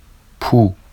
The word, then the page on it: pu: Vafsi